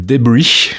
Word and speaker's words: Debris: Alors, ça s'appelle Debris, faut le prononcer à l'américaine.